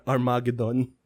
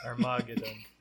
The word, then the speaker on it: Armageddon: Mali ka ng pronunciation, it's Armageddon. – Armageddon. – Yan ang tamang pronunciation.